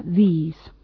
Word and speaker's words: these: Fijaos que es muy parecida la pronunciación entre this y these. La primera es singular y el segundo es plural. Simplemente en el plural, como veis, la vocal I es más larga, these.